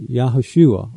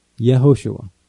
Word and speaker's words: Yehoshua: Due to modern Hebrew grammar rules, most Hebrew-speaking people today would pronounce the name using Yeh at the beginning rather than Yah. In other words, Yehoshua.